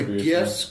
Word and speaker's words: GIF: Oh, the GIFs. – To GIFIC. – I know it's pronounced GIF, but... – I say GIF. – I say GIF, too. – It's graphics interchange format. That's a hard G.